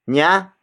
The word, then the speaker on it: niya: Niya is spelled as N-I-Y-A.